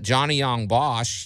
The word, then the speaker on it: Johnny Yong Bosch: And Johnny Yong Bosch, did I say his name right? – Yeah, that's correct.